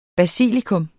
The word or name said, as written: basilikum